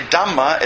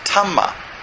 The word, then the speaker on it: Dhamma: If you say Dhamma, it's called, pronounced Tamma. This is not correct.